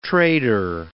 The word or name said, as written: trader